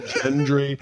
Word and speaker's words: Gendry: Gendry. – Gendry. – The hard G.